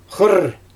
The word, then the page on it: xɯrr: Xyzyl